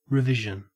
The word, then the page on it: revision: Australian English